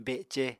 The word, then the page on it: bece: Apatani